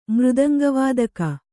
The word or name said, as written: ಮೃದಂಗವಾದಕಿ